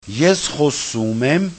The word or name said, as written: eskxosumem